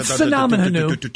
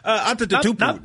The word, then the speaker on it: Antetokounmpo: Sinamon Hanu. – Antetokounmpo.